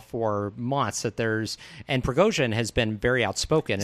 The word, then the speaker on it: Prigozhin: Prigozhin. – Prigozhin.